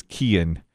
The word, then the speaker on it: Cian: They're going to name him Kian, C-I-A-N, and the pronunciation is Kian.